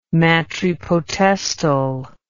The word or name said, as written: matripotestal